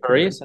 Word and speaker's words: Piraeus: Piaris? No. – Piraeus? Yeah. – Piraeus, that's how you pronounce it.